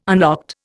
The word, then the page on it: unlocked: Indian